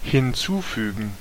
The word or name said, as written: incorporate